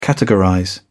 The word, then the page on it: categorize: British English